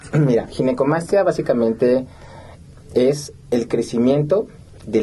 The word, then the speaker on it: ginecomastia: Pues muchas gracias, y sí, definitivamente creo que es una, para empezar la palabra, le decía, ¿cómo se pronuncia? Ginecomastia. – Sí, exacto, es ginecomastia.